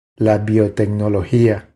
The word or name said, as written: La biotecnologia